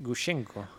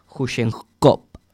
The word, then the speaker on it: Gushchenko: Gushchenko. – G-U-S. H-C-H-E-N-K-O. – Gushchenko. ¿Con V final no? – Gushchenko, no. No, sería Gushchenkov.